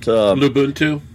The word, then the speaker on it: Lubuntu: Lubuntu, there we go, on there.